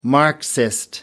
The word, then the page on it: marxist: British English